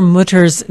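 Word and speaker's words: Mütter: How do you say his name? – I say Mutter.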